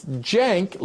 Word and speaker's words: Cenk: It's spelled C-E-N-K. But the C in Turkish is pronounced like a J, like Cenk, like short for Jenkins. Okay? Cenk. Second name, surname is Uygur. U-Y-G-U-R.